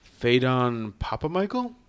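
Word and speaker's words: Phedon Papamichael: One of them is that I find that this movie, the cinematography was done by Phaidon Papamichael. Is that right from a pronunciation standpoint? – Oh, yeah. That's how I'd say.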